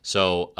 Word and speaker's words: Soule: Soule, I believe it's pronounced.